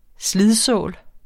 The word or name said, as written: slidsål